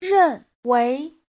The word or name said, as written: ren4wei2